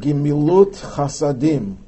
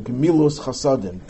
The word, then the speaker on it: Gemilut Chasadim: But very often we use the term for kindness, not chesed, which simply means kindness, but Gemilut Chasadim, or Gemilus Chasadim, if you use the Ashkenazic pronunciation, which literally means bestowing kindnesses.